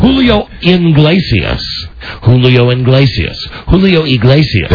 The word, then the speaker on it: Iglesias: Is it Iglesias or Inglesias? – That's why he likes me best, Mike. – Because you mispronounce his name? – Julio Iglesias. – That's correct.